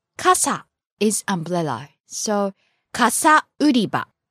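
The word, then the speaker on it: 傘: Kasa. – And then we also learned umbrella department. – Kasa売り場. – Okay, the word umbrella is kasa. It goes from high to low, ka-sa.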